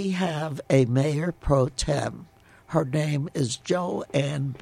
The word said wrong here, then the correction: Johanna: Bencomo. – Johanna Bencomo. – Johanna, yeah.